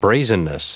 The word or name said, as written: brazenness